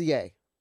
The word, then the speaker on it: Ye: Ye. – Is that what, that's how it's pronounced, Ye. Is Ye.